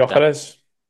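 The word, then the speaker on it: Gyökeres: That Victor Gayo something, Gayo Keres or something like Gyokeres.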